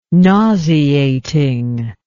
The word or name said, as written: nauseating